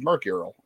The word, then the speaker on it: mercurial: Is that mercurial? I can't speak today. Maybe I need a day off. – Mercurial. – Mercurial. You know, it's not good when Jeff is correcting me on my pronunciation.